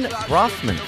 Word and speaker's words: Baughman: We're here with Galen Baughman. His name is spelled not even remotely close to how it's pronounced. – It's true. – You've got like GHs in there that are superfluous.